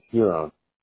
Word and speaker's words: Huron: Is it Huron or Huron? – It's Huron. – It is Huron.